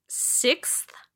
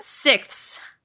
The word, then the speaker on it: sixth: I learned that my entire life, I've never correctly pronounced the word sixth. Sixth. – Sixth. – Sixth. Sixth. Okay, like, yes, that's correct. I've been saying it sixths my entire life. – Sixths? – With an extra S.